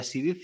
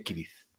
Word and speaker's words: Cirith: No, o sea, mira, yo solo lo digo porque sé que en élfico esa C se pronuncia como una K, entonces no sería Sidith, sino que sería Kirith.